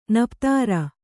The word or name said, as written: ನಪ್ತಾರ